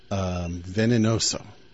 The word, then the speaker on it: venenoso: And that's muy veneroso, which means very venomous, or something like that in Spanish. Venenoso. Yeah, okay.